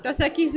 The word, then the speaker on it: Dos Equis: Dos Equis is a beer.